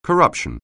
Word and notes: corruption: anglais

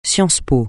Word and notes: sciences-po: francais

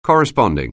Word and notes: corresponding: anglais

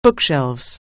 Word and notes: bookshelf: anglais

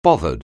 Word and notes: bothered: anglais

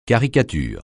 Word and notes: caricature: francais

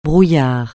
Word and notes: brouillard: francais

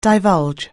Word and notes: divulge: anglais

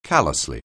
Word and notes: callously: anglais

